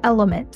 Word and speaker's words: LMNT: This podcast is brought to you by Element, that's spelled L-M-N-T, which is a company that I had the chance to invest in and have loved since day one.